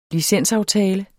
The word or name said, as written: licensaftale